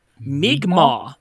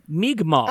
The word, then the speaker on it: Mi'kmaq: It's Mi'kmaq. There you go. Mi'kmaq. – Ah, Mi'kmaq. – Interesting. I would not have assumed from the apostrophe K-M-A-Q. That's just my white pronunciation.